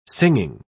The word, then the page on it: singing: British English